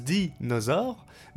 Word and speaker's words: dinosaure: Un os de Danisor ?» Balbutia la petite fille. non. Déjà, ça se prononce dinosaure, mais c'était bien plus extraordinaire que ça.